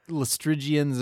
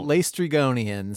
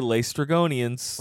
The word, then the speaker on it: Lestrygonians: The Lestrygians, or whatever they're called, Lestrygonians says the Odyssey pronunciation guide that we got from an elementary school website. – Okay, Lestrygonians.